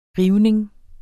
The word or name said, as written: rivning